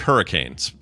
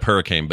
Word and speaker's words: Hurricane: It's spelled like hurricanes, straight up hurricane, but it's pronounced hurricane.